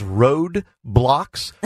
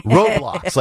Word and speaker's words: Roblox: I thought it was Road Blocks, but it's Roblox, like R-O, and then I think B-L-O-X.